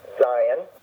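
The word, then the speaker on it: Zayin: Remember, it's a two-syllable word, so the accent will be on the first syllable. Zion, then Chet, Chet, then Tet, and then Yud.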